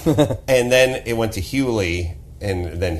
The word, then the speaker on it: Hughley: Hughley? – Hughley.